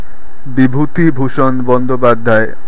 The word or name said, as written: Bibhutibhushan Bandopadhyay